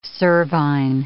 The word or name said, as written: cervine